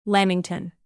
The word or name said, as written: lamington